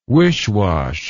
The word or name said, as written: wishwash